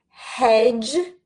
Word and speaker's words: hedge: Hedge, head. Now, hedge, again, we have this D, so it's spelled E or H-E-D-G-E. This D is silent. We do not pronounce it, but we write it.